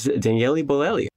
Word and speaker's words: Daniele Bolelli: He is the host of History on Fire, this is Daniele Bolelli.